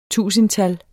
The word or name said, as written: tusindtal